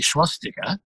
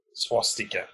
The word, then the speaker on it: swastika: Well, I think we talked about this one before, because the Nazi swastika. What do I say? Do I say that wrong? – Yeah. – How do you say it? – Swastika. There's no sh. – Swastika.